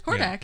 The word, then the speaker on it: Hordak: Hordak, yeah.